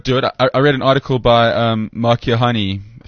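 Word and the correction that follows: Keohane: if I'm pronouncing his surname wrong, I'm sure I am. – Kiohane. – Yeah, one of them. Mark. Kiohane. Right.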